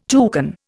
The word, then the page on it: toucan: Indian